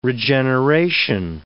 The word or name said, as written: regeneration